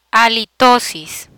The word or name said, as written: halitosis